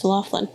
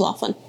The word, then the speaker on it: Loughan: I thought it was Laughlin. – No. – L-A-U-G-H-L-I-N? – It's pronounced with a K, though. It's that kind of... Yes, Scottish pronunciation. Or pronunciation.